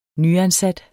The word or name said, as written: nyansat